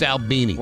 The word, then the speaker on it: Albini: It's Albini.